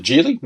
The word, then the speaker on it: Geely: They actually pronounce it Geely, not Geely, but yeah, there is the bigger batteries on the way out.